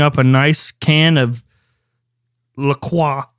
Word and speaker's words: La Croix: La Croix. You have to say it like that.